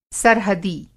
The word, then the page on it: सरहदी: سرحدی